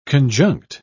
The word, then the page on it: conjunct: British English